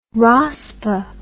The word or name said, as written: rospa